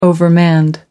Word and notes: overmanned: American English

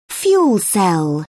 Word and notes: fuel cell: British English

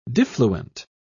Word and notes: diffluent: British English

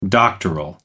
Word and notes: doctoral: British English

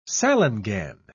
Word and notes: salangane: British English

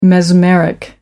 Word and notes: mesmeric: American English

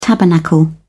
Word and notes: tabernacle: British English

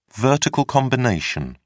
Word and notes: vertical combination: British English

